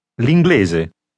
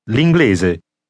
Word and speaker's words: l'inglese: Once again, say English, meaning the language. – L'inglese. L'inglese. L'inglese.